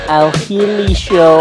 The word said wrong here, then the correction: Ahli: Am I saying this football club team name right? Oh, Ali. It's spelled A-H-L-I.